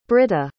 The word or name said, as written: brita